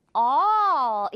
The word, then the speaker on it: all: 모든, 전부의라는 뜻으로 all 이렇게 발음돼요. 이 단어는 그리고 길어요, 발음이. 이렇게 짧지 않고 all. 절대 여러분 all 아니죠. All.